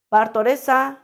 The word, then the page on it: bartoresaa: Macuiltianguis